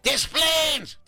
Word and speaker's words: Des Plaines: Just think like tattoo. Desplaines. But with an S.